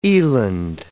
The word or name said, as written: eland